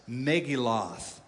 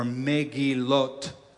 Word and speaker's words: Megillot: This book, the Book of Lamentations, is part of a section of the Bible called the Megillah. I'm pronouncing it, Americanizing it on purpose. Megillah, or Megillot is the Hebrew pronunciation.